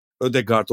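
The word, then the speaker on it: Ødegaard: Bu arada biliyorsunuz Danimarka'la biz hani geçen sene de Jonas Vingegaard'ı anlatırken sondaki bu guard sonuçta benzer kültür, Viking kültürü ve dil kökleri benzer Ford. yakın yani Danimarka diliyle Norveç dili. Ödegau gibi bir aslında okunuşu olabilir mi diye. Öyle. E, şüpheleniyorum Öyle. ama tabii bu muhtemelen Anglo-Sakson basının ve anlatımın daha çok Ödegard olarak entegre olduğu için bundan vazgeçilmiyor gibi bir durum olma ihtimali yüksek.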